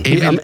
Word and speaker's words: Evelyn: Evelyn. Englisch ausgesprochen.